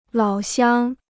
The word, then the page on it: 老乡: lǎo xiāng